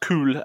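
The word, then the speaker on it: Kül: So yeah, pronounced cool, uh, which is K U with the umlaut L uh, if you're looking it up, or ashes.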